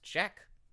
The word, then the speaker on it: Jakues: You spell Jack weird. J-A-K-U-E-S. – It's pronounced Jack.